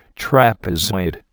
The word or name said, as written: trapezoid